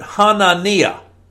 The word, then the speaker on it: Hanania: and I like the way you pronounce the word in your language because it's similar to the way it would be pronounced in the Hebrew. I think as you were reading it, I heard Hanania. That's a translation of the Hebrew, Hanania. It's what that's the way it's pronounced.